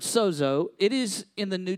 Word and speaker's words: sozo: It's actually spelled just S-O-Z-O, but it's pronounced sozo, okay?